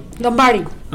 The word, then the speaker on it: Lombardi: Lombardi.